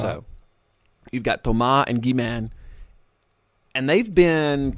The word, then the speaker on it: Thomas Bangalter: But the tall silver one, his name in real life is, it's spelled like Thomas, but you pronounce it Thomas. And his last name is Bangalter, or Bangalter. And they're French, so that's why it's Thomas Bangalter.